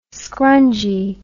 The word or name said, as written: skrungy